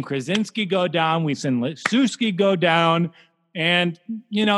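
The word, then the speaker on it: Lischewski: Christopher Lishwecki. How do you say that name? Leshewski. Oh, man, even better. Leshewski, yeah, sounds like something you would name a pet tuna.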